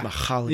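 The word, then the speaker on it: Mihály: You actually like went deep on the H there. – Yeah. – Mihaly.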